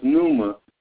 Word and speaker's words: pneuma: And see, one thing I will say this morning, the New Testament word Greek, pneuma, pronounced pneuma, N-E-W-M-A-H, indicates breath, wind, spirit, pneuma.